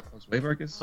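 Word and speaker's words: Josué Vargas: Uh, Jos, was it Josu Vargas? – Josue Vargas. – Josue Vargas.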